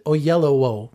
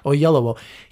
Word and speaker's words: Oyelowo: And I'm David Oyelowo. That's how you say his name. – Yeah. – Oyelowo.